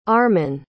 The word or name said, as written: arman